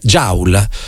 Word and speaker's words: joule: E se questa persona, invece di prendere, si pronuncia joule, mi dite? Bene, vi ringrazio. Quando combattete la mia ignoranza, joule, benissimo, se si pronuncia joule vi credo.